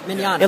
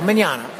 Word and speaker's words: Mignogna: It looks pretty scary to look at all the letters that you don't pronounce. – Right. – It's Italian. You know, those crazy Italians. – Mm-hmm. – They throw a lot of extra letters in there, but it's very simple. Mignogna.